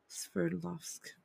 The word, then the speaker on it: Sverdlovsk: Sverdlovsk.